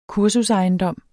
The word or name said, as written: kursusejendom